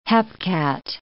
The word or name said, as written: hepcat